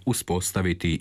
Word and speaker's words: uspostaviti: Primjerice, morfonološke zapise uzpostaviti i potpis izgovaramo uspostaviti i potpis, kako se piše i izgovara u fonološkom pravopisu.